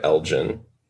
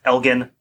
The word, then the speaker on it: Elgin: So we are aware that the Lord and Lady is pronounced Elgin, as in like the Elgin marbles, which I believed were acquired by this guy's father. But we will be using the American pronunciation of Elgin, because I don't think we could train ourselves to say the British way.